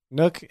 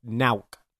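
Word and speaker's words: Nøkk: Which okay, so we asked our Danish friend who natively speaks Danish how to pronounce Nook, and he said Nauk.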